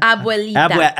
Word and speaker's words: Abuelita: And am I saying that correctly? Abuelita? – Abuelita. – Abuelita. – Yes.